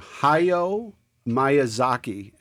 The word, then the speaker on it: Hayao Miyazaki: And the writer-director is a man named Hayao Miyazaki, and for those of you who are anime fans, forgive me if I butchered that name, but this is I think you said it correctly.